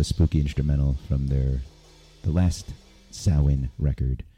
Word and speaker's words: Samhain: So I'm going to play what immediately comes to mind during spooky season is a band called Samhain. Looks like Samhain, but it's pronounced Samhain apparently if you're Celtic.